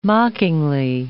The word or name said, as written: mockingly